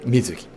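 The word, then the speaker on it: 水月: さらに日本にわずか 10 人しかいないシニア睡眠改善指導員資格を取得し、現在も主催しておりますけども、快眠サロン水木、水の月と書いて水木と読みますが、をオープンしました。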